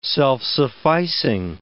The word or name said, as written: self-sufficing